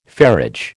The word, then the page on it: ferriage: American English